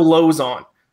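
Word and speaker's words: Lauzon: Even though you were still mispronouncing it, it's Joe Lozon.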